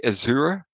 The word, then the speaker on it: Ezeru: In the past, it, there, it's pronounced the Azura, E Z E R U.